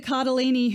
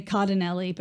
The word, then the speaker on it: Cardellini: Happy birthday, Linda Cardellini, who every time I say her name, I say Cardinelli, but this time I read it as I said it.